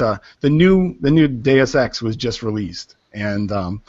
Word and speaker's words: Deus Ex: Do I don't know if you guys play video games, but uh, Deus Ex. I'm probably saying that wrong. Deus Ex. – Deus Ex. – Deus Ex. – Yeah.